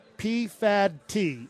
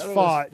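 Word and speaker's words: Pfaadt: And number five is Brandon P-Fad-T, if you want to just pronounce it the way they spelt it. Fought? – It's Fought.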